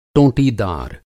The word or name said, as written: टोंटीदार